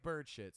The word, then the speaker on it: Burchett: He Keith said Burchett. to pronounce it like bird shit, so we're just gonna say your bird shit.